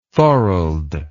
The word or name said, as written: thorold